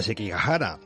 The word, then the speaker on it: Sekigahara: Pues mira, Daniel, pues Sekihara, eh, que lo he dicho mal, nos cuenta una historia de guerra, honor y amor y que tiene lugar en la famosa batalla de Sekigahara, en el año 1600, un gran conflicto en el que se enfrentaban las dos principales facciones y ejércitos del Japón.